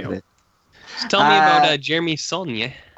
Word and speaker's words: Saulnier: I'll move on to A Murder Party by Jeremy, I don't know how to even bother pronouncing it. Oh, Saulnier. – Yeah, there you go. – Saulnier.